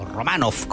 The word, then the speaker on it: Romanov: Nicolás Romanov, o Romanov, como debiera ser la pronunciación eslava.